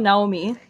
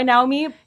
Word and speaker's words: Naomi: I've recently started going by Naomi because I live in Israel and that's how they pronounce the name.